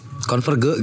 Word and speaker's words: Converge: Zao terus air 90-an ada yang namanya Conver. Converge gitu bacanya, Converge.